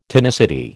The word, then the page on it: tonicity: American English